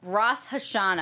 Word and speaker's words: Rosh Hashanah: it's Rosh Hashanah, I'm pretty sure is how it's, I think. – Okay. – Someone will tell me I'm wrong. – But that's right.